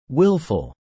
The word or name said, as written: willful